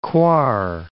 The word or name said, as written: Coire